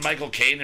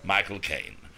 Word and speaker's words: Michael Caine: It's just like the way to really, they always say is just say my cocaine.